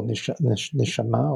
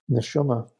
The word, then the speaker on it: neshama: In Judaism, we have a nice expression that the shorosh ha-nishama, the root of one's soul, and the spiritual soul, nishama, or those who are familiar with the term from its Yiddish pronunciation, neshama, is nurtured by all experiences.